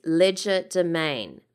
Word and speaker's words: legerdemain: So the word is legerdemain but if you were going to pronounce it with in the French, you would probably say legerdemain.